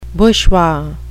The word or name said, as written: bushwa